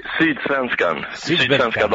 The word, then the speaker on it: Sydsvenskan: ¿Cómo se dice tu periódico? Que no me voy a atrever yo. – Sydsvenskan, ¿no? Dagbladet.